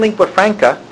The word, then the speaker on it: lingua franca: The coexistence of one lingua franca, did I pronounce that right? – Yes.